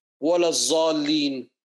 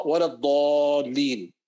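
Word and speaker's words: wala dhalin (ولا الضالين): So you don't say, wala zalin, wala dhali, wala dha, because it's a dha, wala dhalin, etc.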